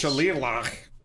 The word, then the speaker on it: shillelagh: Is that actually Shillelagh? Because if so, then that word is way harder to pronounce than it is to spell. and also the other way around. – No, it's way harder to spell than pronounce. – Yeah. – Phonetically, it's Shalilah.